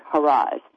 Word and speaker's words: Haraz: And her name is spelled Rita, the traditional way, R-I-T-A, and her last name is Haraiz, even though it's pronounced like Haraz.